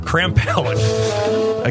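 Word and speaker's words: Krampelj: Martin Crampelge.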